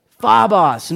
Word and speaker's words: phobos: You want to say phobos? Phobos. Nice, good pronunciation.